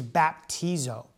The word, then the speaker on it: baptizo: baptizo. I mean, we would pronounce it, it's B-A-P-T-I-Z-O, I guess is what we would look at for the English translation, baptizo.